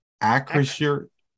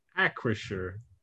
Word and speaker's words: Acrisure: at Cruiser Stadium. Am I pronouncing that right? Do you guys know? At Acrisure Stadium. Does anybody know what that industry is? – Acrisure.